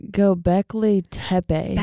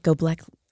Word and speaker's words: Göbekli Tepe: It looks like Göbekli Tepe. – That's it, Göbekli.